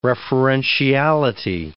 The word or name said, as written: referentiality